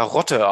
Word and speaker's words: Lotte: Und das Lustige ist ja, dass man in Korea und in, in Japan spricht man es ja Rotte aus, weil es das L ja dort nicht gibt.